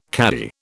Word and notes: caddie: British English

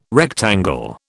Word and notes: rectangle: British English

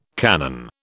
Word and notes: Canaan: British English

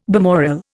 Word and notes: balmoral: Indian

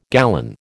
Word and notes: gallon: American English

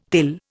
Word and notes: thill: Indian

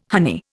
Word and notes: honey: Indian

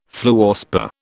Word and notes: fluorspar: British English

